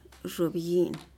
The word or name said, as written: zhubyi'in